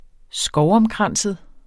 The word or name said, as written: skovomkranset